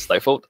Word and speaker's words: Stifled: Stifled gets pronounced as Stifled a lot of times, but don't do that. It's Stifled. And then Muffled Warfare.